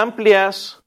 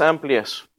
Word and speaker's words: Amplias: A Amplias, o Amplia, yo creo que es Amplias, porque no tiene acento en la I.